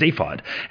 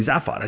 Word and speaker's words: Zaphod: I think it's Zaphod, and they said, well, no, it's the way it's spelled, it's clearly Zaphod. I said, well, but it's the book is just a basically a version of the radio program that came first, and I've heard that, and it's SafeOn, and I've heard the author actually speak. It's like an audiobook version, and there's a television show. Like, there's a lot of evidence here that I'm correct about the pronunciation of the name.